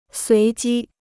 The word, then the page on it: 随机: suí jī